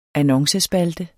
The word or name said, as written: annoncespalte